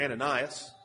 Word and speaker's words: Ananias: And he tells Ananias, or Ananias, probably better pronunciation, he tells Ananias to seek out Saul, and understandably, Ananias is very reluctant to do this.